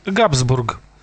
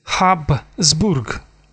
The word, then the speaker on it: Хабсбург: А что означает Габсбург? То есть Хабсбург.